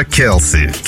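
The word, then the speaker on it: Kelsee: Просто перейду к последней на сегодня песне под названием «Кто я без любви?», а это артистка по имени, как же это правильно сказать, KLC. Пишется как K-E-E-L-S-E по-английски. Знаешь, как сказать, а писать попытался, да? Келси.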